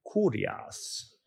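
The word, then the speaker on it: kurios (κύριος): It kind of sounds like curious, but that's not the right way to say it. You got to say it like kurios, right? So that will make you sound like an ancient Greek.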